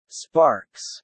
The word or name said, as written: sparks